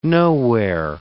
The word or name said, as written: nowhere